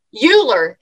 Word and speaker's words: Euler: And now that I've put that image in your head, you're going to meet Kurt Euler. It's not Bueller. It just rhymes with it.